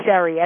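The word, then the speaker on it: Sherri: And my name is spelled K-A-N-E, by the way. It's Sherry, S-H-E-R-R-I-K-A-N-E.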